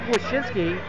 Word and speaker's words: Wyshynski: We are joined by Puck Daddy from Yahoo Sports, Greg Wyshynski. Is that right? Is that how you pronounce it? – That is indeed how you pronounce it.